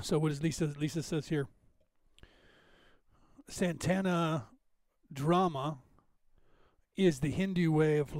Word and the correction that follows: Dharma: Did I say that right? Santana Dharma. Sorry, I didn't see the R there.